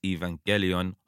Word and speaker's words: Evangelion: So I have it on very good authority that it is Evangelion, hard G, like go.